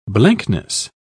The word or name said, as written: blankness